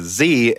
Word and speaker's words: Xe: I actually agree with you when it comes to saying the Intel graphics lineup as Z instead of XE. The official pronunciation of XE is XE. X to the E, though, maybe he means.